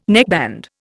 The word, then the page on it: neckband: Indian